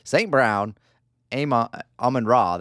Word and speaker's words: Amon-Ra St. Brown: St. Brown, Amon-Ra.